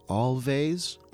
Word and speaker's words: Alvvays: Before that, we heard Archie Marry Me from a group called Always, although they spell their name A-L-V-V-A-Y-S, so it could be actually pronounced Always, depending on whether you're in Scandinavia or not, I guess.